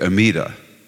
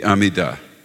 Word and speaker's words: Amidah: If you're Ashkenazic, Amidah. We put the accent on the last in Israel and Sephardic. The Amidah, the Shemona Esrei.